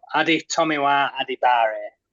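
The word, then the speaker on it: Adetomiwa Adebawore: I've got it spelt out here phonetically, ready to go. So we've got Adi Tomiwa Adibare, been told.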